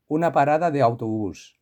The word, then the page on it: la parada de autobus: Spain